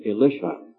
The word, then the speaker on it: Elisha: And being a Scotsman, I pronounce his name Elisha. It helps us to distinguish at least between Elisha and Elijah.